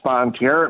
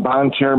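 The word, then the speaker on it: Bonne Terre: We've got pastor at St. Matthew Lutheran Church in the Missouri town of, let's see, would this be pronounced Bonterre? How do you guys say this French name? Good morning, brother. – Good morning, brother. Yes, it is pronounced Bonterre. It means the good land.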